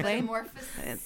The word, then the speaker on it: metamorphoses: Keep in mind that we go through many metamorphoses. I'm not even sure I'm saying that correctly. – Metamorphoses.